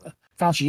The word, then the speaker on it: Fauci: And Biden also said that as soon as it's available, and Dr. Fauci, I'm not sure if I butchered his name or Fauci? – Not. But Fauci, yeah.